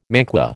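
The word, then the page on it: manqué: American English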